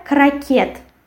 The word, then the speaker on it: крокет: Then we have, after muesli, we have croquette. In English it will be croquet. And in Russian it will be croquette, and we write t and we pronounce t. And the stress falls on the last syllable.